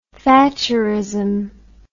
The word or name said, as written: thatcherism